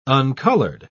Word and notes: uncoloured: British English

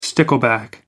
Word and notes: stickleback: American English

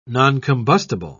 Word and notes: noncombustible: British English

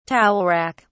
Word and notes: towel rack: British English

